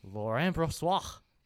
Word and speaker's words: Laurent Brossoit: Let's talk about how we finally might have a capable backup goalie in Laurent Brossoit.